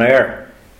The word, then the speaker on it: air: A-I-R, pronounced air.